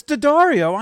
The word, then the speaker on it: D'Addario: D'Addario? It's D'Addario.